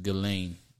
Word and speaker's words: Ghislaine: I don't know how you get Ghislaine from G-H-I-S-L-A-I. So all that shit in the middle is silent. That, yeah. Yeah, his H-I-S is silent. It's just Ghislaine, G-L-A-I-N-E.